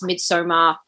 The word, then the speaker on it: Midsommar: And there has been a bit of like debate over whether it's pronounced Midsommar or Midsommar. Apparently, either it works. – Either it's fine, but I see Midsommar because Midsommar is our LGBTIQA plus festival here in Victoria, and I won't be having that happen. – The director pronounces it Midsommar, so I think we should go with that.